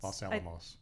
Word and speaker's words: Los Alamos: Los Alamos.